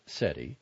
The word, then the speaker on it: CETI: The acronym is pronounced SETI, and purposely recalls SETI, S-E-T-I, the search for extraterrestrial intelligence.